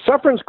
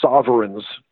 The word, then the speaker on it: Suffern: Um, most of them mispronounce the name of it. They think it's called Sovereign's clove, which is sort of ironic.